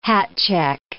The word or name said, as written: hatcheck